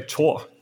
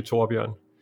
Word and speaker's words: Thorbjørn: But yeah, I mean, if I was pronouncing it in Danish, I would say Tor, right? Something like that? Yeah, we would say Tor, Tor. But nobody calls me that in Denmark. Everyone calls me Torbjörn.